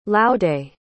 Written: laude

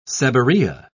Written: seborrhea

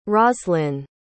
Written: roslyn

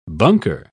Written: bunker

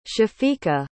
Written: shafiqa

